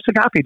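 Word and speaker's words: Saghafi: I think it's #Sagafi, just my name.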